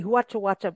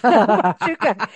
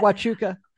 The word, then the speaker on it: Huachuca: I want to say Huachuacha, but that's not it. Huachuca.